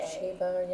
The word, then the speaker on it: Ceiba: Another concert happening is Mirabai Chiba. I don't know if I'm saying that right. Mirabai C-E-I-B-A. – Chiba, yeah. Chiba, maybe? Yeah.